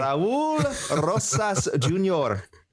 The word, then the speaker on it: Raul Rosas Jr.: Raul Rosas Jr. I also just wanted to show my excellent pronunciation of the young man's name. – The rolling of the R's was just peak.